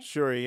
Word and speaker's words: Shuri: Shuri, yeah.